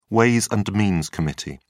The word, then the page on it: ways and means committee: British English